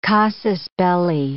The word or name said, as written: casus belli